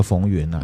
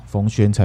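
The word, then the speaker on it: 冯谖: 再来就是有一位听友呢，我觉得他很 nice 啊，他都给我们五星，嗯、可是他有提出一些纠正、嗯、啊，譬如说蚂蚁那集呢，我讲到那个冯源呐，嗯嗯啊，其实那个源念成轩呐，冯轩才对。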